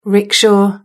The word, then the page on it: rickshaw: British English